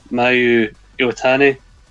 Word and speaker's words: Mayu Iwatani: And I apologise dreadfully if I butcher his name, Mayu Iwatani. Is that how you pronounce it? – Yeah, spot on.